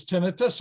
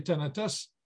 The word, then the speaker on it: tinnitus: And I also have tinnitus, which you can pronounce as tinnitus or tinnitus. Either way is correct. I say tinnitus, which is a ringing, roaring, humming, hissing, buzzing sound in your ears.